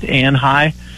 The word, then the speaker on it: Anhui: One of the major provinces, and I don't know if I can pronounce it right, Anhui, A-N-H-U-I province.